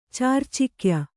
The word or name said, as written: ಚಾರ್ಚಿಕ್ಯ